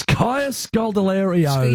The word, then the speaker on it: Kaya Scodelario: Oh, no, I'm probably going to pronounce her name wrong. It's Kaya Scodelario's. – Yeah. – Nailed it.